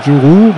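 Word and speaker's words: Djourou: Juru, oder wie wird da ausgesprochen? Juru. Juru, genau. Dann auf Gideon Jung.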